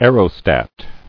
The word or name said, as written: Aerostatic